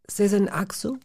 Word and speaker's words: Sezen Aksu: Sezen Aksu? – Sehr gut. Set wird es ausgesprochen, genau.